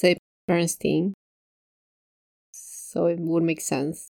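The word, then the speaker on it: Berenstain: No, it's pronounced Bernstein. And I checked on the commercials for the TV show. They have the little theme song and they say Bernstein Bears.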